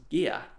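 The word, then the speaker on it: Gigha: and its name is pronounced Gia.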